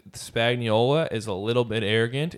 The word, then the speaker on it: Spagnuolo: How do you say the defensive coordinator? – Spagnuolo. – Spagnuolo.